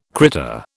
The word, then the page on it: critter: British English